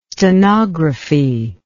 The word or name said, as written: stenography